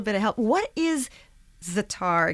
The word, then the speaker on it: za'atar: Za'atar, yeah. – Za'atar.